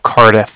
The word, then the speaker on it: Cardiff: And then, yes, the Bank of Cardiff is spelled C-A-R, D as in dog, I, F-F as in Frank Frank. So it's like car and then different. – Okay, so you said C-A-R, B as in boy, I-S-S? – D as in dog.